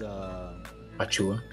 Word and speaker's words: Achiuwa: They got Thaddeus Young, Precious Achiuwa, I think that's, I hope I pronounced his name right, but yeah. He's Achiuwa, yeah? – Yeah.